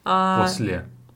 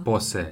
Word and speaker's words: После: После. – What did I... Что я сказала? – После. It's just the wrong pronunciation.